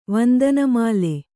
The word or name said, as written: ವಂದನಮಾಲೆ